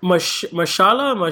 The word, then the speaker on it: Mahershala: Mahershala. Mahershala Ali? He's the man from Moonlight. And he's also from Luke Cage. – Yeah, he's a Cottonmouth in Luke Cage. – Exactly. Mahershala Ali.